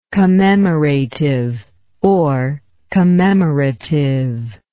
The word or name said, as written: commemorativeness